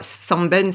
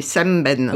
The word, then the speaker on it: Sembène: Alors, c'est ce qu'on prononce normalement, c'est pas Sembène, c'est Sembène.